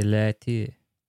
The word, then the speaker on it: Leite: I actually messaged Jesse Light. Is that his name? – Jesse, Light? – Yeah. – Oh, how do you say his name? – It's French, man. It's Jesse Leite. – Leite. – L-E-I-T-E.